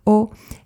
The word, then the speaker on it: awe: Y nos enseñaron los efectos de lo que en aquel entonces era la incipiente ciencia del asombro, o en inglés O, que se escribe A-W-E nos hicieron, nos sentaron, nos hicieron ver unos vídeos de paisajes impresionantes, ¿sabes?